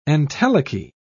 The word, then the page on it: entelechy: British English